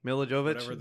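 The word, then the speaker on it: Milla Jovovich: Mila Jovovich.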